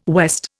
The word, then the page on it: west: Indian